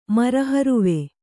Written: ಮರಹರುವೆ

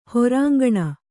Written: ಹೊರಾಂಗಣ

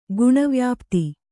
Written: ಗುಣವ್ಯಾಪ್ತಿ

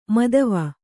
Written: ಮೋದವಿ

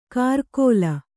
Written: ಕರ್ಕೋಲ

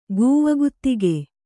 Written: ಗೂವಗುತ್ತಿಗೆ

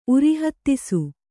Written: ಉರಿಹತ್ತಿಸು

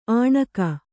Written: ಆಣಕ

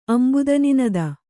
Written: ಅಂಬುದನಿನದ

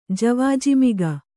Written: ಜವಾಜಿಮಿಗ

